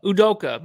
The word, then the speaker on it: Udoka: You said it's gonna be Adoku, right? Is that who you're giving it to? – Yeah, how do you say his name? – Azubuke. Udoka. Udoka, my bad.